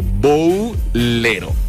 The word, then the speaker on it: Bowlero: Ahí está la dinámica, ahí está el post, ahí está el póster de Boulero, iba a decir Boulero, se escribe B-O-W-L-E-R-O, Boulero, ¿ok?